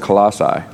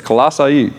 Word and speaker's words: Colossae: So the word Colossae is how it's pronounced, by the way. So if I slip, I've always said Colossae, but it's Colossae.